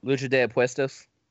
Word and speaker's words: Lucha de Apuestas: He is 0-1 in Luchas de Apatas matches. I'm pretty sure I mispronounced that. – Lucha de Apuestas? – Yes.